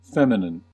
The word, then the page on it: feminine: American English